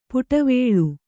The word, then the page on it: ಪುಟವೇಳು: puṭavēḷu